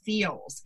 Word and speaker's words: Feals: And one of the things I want to thank, you know, Erin, our sponsor today, because we have this wonderful company and it's called Feels, and it's spelled F EALS.